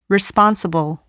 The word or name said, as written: responsible